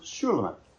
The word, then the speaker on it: Shulamith: Once again, her name embodies the same consonants as Solomon's name, but feminized in its vowels so that we pronounce it Shulamith.